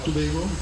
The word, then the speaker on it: Tobago: Zeg ik het goed, Tobago? Ja.